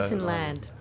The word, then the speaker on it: Newfoundland: Newfoundland. I probably pronounced that wrong. It's spelled Newfoundland, but it rhymes with understand. In Canada. – Newfoundland.